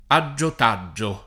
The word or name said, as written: aggiotaggio